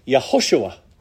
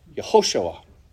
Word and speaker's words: Yehoshua: So the name which we pronounce as Jesus is the English translation of the Hebrew name Yehoshua. Yehoshua.